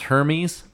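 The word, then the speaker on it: Hermès: It's like the most expensive kind of purse made by Hermes, a French designer. – I believe it's pronounced Hermes.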